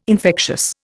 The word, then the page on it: infectious: Indian